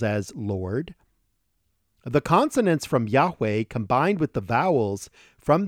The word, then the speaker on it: Yahweh: The exact pronunciation of this is widely debated, as are all aspects of this word, but I pronounce it Yahweh. Without going into the details, Yahweh is the one and only true God,